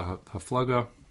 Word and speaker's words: HaFlaga: and the generation of the HaPlaga or the HaFlaga, how's typically pronounced, is like the Dor HaFlaga is the like the Tower of Babel, that generation after Noah, they got it.